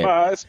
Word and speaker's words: Sotheby's: How do we say Sotheby's.